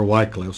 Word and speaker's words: Wycliffe: John Wycliffe, for example, or Wycliffe, sometimes I've heard it pronounced,